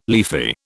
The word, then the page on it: leafy: British English